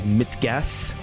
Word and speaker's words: Mit Gas: The album is called Mitgas.